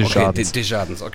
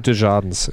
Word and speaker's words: Desjardins: Der Spieler, darf ich nochmal kurz, der heißt Desjardins, der wird so merkwürdig ausgesprochen. – Okay. Desjardins. – Andrew klar. Desjardins, ja.